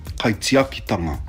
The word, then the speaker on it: Kaitiakitanga: Es ist Kaitiakitanga geworden. Oder, warte, richtig ausgesprochen? – Kaitiakitanga.